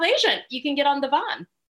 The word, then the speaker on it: Devon: Yeah, Devon, D-E-V-O-N, is our big street, and we pronounce it the Van